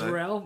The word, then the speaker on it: Rowell: We've worked out now, yeah, how to say his name. – It is Rowell.